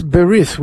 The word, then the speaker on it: berith: So that was, it's called the bris, but in Hebrew, in the traditional pronunciation, it's berith, which means the covenant.